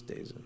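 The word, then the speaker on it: DAZN: How did you think it was pronounced? – Like Dazit or something? – No, it's Dazit.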